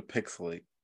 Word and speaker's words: PixelHate: Welcome to Pixelate, where the H is silent, but the hate is real.